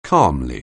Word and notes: calmly: anglais

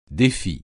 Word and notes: défi: French

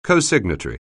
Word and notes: cosignatory: anglais

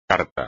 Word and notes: carta: espagnol

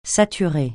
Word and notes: saturer: francais

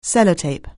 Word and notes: Sellotape®: anglais

